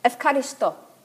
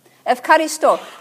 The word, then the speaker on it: Ευχαριστώ: But you don't say Eucharist. You say Ευχαριστώ. Ευχαριστώ. Now, do you see an F in there, in the Greek? But that's how they say it, Ευχαριστώ.